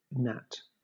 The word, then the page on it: gnat: British English